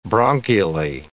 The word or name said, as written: bronchially